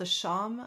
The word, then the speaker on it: shawm: So the oboe, I think the ancestor is called the shawm.